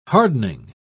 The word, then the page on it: hardening: British English